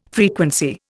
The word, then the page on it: frequency: Indian